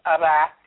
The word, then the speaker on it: Iraq: So, you've just given me, I really didn't know that it's Iraq and not Iraq.